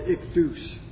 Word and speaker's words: ichthys: Well, the Greek word ichthys is fish, so they call it the ichthus. It's probably a better pronunciation.